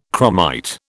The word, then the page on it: chromite: British English